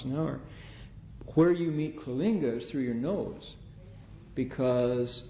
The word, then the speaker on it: Coalinga: Um, if you take Highway 5 north from LA, you go past Coalinga, C O A L I N G A, Coalinga, right? Pronounced Kalinga.